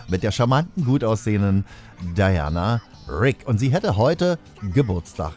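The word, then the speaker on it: Diana Rigg: Und dann möchte ich noch erinnern an Diana Rick. Ja, Diana Rick, vielmehr ausgesprochen, ne? Ist ja Englisch.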